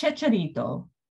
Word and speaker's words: Chicharito: So I guess the story behind their friendship is Checarito is also from... Am I pronouncing that right? – I think Chicharito. It's Chicharito.